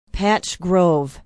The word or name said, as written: Patch Grove